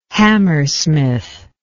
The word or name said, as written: Hammersmith